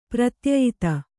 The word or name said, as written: ಪ್ರತ್ಯಯಿತ